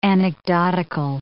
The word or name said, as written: anecdotical